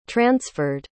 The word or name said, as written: transferred